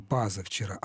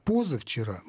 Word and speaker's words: позавчера: Вот это вторичное ударение на первом слоге, оно не даст нам гласного «а». Мы не скажем «пазавчера», а вот «позавчера» мы можем сказать.